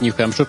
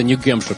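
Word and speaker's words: New Hampshire: Коннектикут, Нью-Хэмпшир. Почему-то по-русски это Нью-Гэмпшир написано. А Массачусетс и... – Поэтому и разрешили. – Не Геймпшир, а... – Ну, потому что гей произносит как гей, а не хей.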